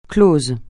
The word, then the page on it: clos: francais